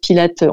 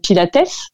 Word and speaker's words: Pilates: la contrologie, donc c'était voilà Joseph Pilate, on devrait dire même Pilates, ça s'écrit avec un S.